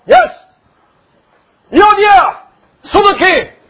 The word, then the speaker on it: Syntyche: And I beseech Euodia and beseech Syntyche. You pronounce it differently. But the Greek pronounce as syntyche.